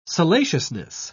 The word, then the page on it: salaciousness: British English